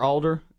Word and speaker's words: alder: Or alder? – Yeah. Yeah, same. Spelled just like your name, but maybe it's pronounced Alder.